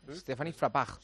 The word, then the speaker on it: Frappart: Stéphanie Frappart.